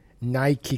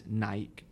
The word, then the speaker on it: Nike: In the UK, it is pretty much everybody says Nike, but I can tell you, it is actually Nike. – And what reason? – I believe it was the Greek goddess of victory, which I think you pronounce actually Nike, but gets called Nike, so therefore it's Nike.